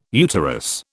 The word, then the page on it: uterus: British English